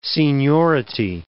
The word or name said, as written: seniority